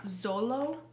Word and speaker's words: Xolo: His name is Zolo Meridueña. – Zolo? – Zolo. Yeah. – Okay. Yes. – X-O-L-O.